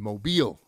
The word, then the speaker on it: Mobile: It happened, and I'm going to pronounce this right, in Mobile. – Correct. – Did I do that one right, Steve? – You got it.